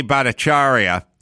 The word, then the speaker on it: Bhattacharya: Use Jay Bhattacharya, or if I'm saying that right. – Correct.